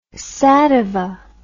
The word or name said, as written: sativa